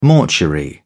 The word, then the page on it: mortuary: British English